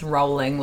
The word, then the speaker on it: Rowling: also, I just had to Google how to say her last name, and it's rolling like you're rolling a ball. – Roll, yeah. – And I was like, I'm never going to remember this. – Like a rolling stone.